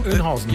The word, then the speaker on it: Oeynhausen: Wie heißt das? Wie würdest du es denn aussprechen? – Was denn bitte? Kreuz? – Kreuz Bad Oeynhausen. Auch so? – Oeynhausen. – Ja, genau. Mhm.